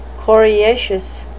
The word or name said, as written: coriaceous